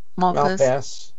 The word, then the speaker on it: Malpass: And here's another one, World Bank President David Malpass. Did I pronounce that right? Malpass? – Malpass, yeah, Malpass.